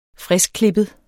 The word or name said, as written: friskklippet